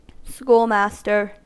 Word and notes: schoolmaster: American English

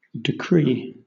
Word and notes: decree: British English

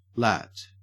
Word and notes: lat: Australian English

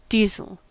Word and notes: deasil: American English

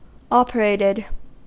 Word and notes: operated: American English

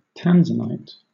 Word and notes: tanzanite: British English

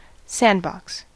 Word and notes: sandbox: American English

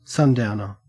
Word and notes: sundowner: Australian English

